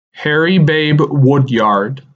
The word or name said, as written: Harry ‘Babe’ Woodyard